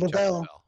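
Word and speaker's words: Leavell: And then that was the year the Allman Brothers, Chuck Leval, how do you say his last name? – Chuck Leval. – He joined them. – Or Chuck Lavelle, excuse me. – Lavelle.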